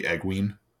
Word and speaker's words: Egwene: Should be Egwene. E-N-E should be a hard E sound.